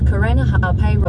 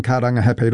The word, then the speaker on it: Karangahape: That was TVNZ's Māori Issues reporter Tianiwa Huri Hanganui driving around Auckland and unimpressed with the navigation voice on the Google Maps app mispronouncing Karangahape Road and other places with Māori names.